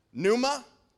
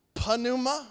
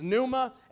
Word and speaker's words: pneuma: In the New Testament, the word is this right here is the word pneuma, not panuma. all right? The P is silent, so if you want to sound smart, there's a Greek word for you. Uh, it's pneuma, and look what it means.